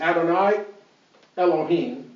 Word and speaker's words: Adonai Elohim: Here's, in talking about the who's, so Lord God in Hebrew is Adonai Elohim, is how they pronounce it.